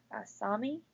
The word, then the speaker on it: Asami: Is it Asami? – Asami.